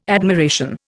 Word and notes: admiration: Indian

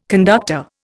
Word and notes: conductor: Indian

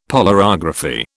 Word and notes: polarography: British English

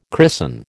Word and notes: christen: American English

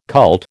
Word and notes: cult: American English

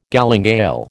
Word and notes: galingale: American English

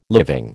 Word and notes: living: American English

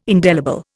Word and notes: indelible: Indian

